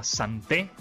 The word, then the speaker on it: Santé: Se llama Santé, así como suena, con acento en la E.